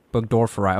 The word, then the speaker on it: Burgdorferi: Burgdorferi.